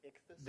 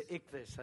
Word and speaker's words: ichthys: The ichthys. – The ichthys.